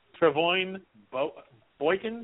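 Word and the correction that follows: Trevone Boykin: Trevon Boykin.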